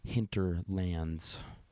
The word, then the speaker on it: Hinterlands: And his podcast is called The Hinterlands, which is like Hinter rhymes with winter, starts with an H. Hinterlands,